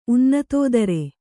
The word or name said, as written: ಉನ್ನತೋದರ